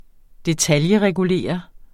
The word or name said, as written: detailregulere